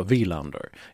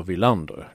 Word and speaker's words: Wilander: Och de flesta uttalade rätt, de, bara, de betonade om de fel, de sa Wilander. istället för Wilander.